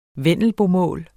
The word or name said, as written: vendelbomål